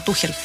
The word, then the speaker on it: Tuchel: Horrible error de Inglaterra, de Tuchel, como le decimos. – Tuchel, está bien pronunciado, Tuchel.